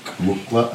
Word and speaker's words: muqw'lha: Mukla or mukla? – Mukukla.